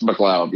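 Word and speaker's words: MacLeod: Is that how you say it, McLeod? – It's McLeod.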